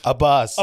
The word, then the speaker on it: Abbas: Abbas.